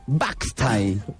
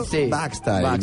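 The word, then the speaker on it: boxty: Pero se pronuncia, porque esto es gaélico, para el que no sepa gaélico, que parece mentira también en vosotros, se dice Boxtai. – Boxtai. – Como Baxter, pero Boxtai, ¿no? – Un poco más suave.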